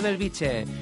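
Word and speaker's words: Bellvitge: Belviche. Lo he dicho bien, ¿verdad, Nelson? – Sí, correcto, correcto.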